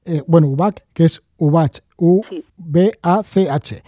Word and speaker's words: Ubach: Sí, mira, yo tengo, yo tengo la página web que es eh, Chris Ubac. Eh, Chris Ubac se escribe con K y acabado en CH.